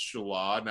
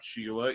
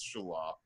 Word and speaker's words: Shelah: And I have another son, Shelah, which, you know, I'll pronounce Shelah, not Sheila, even though it's spelt that way. It's Shelah.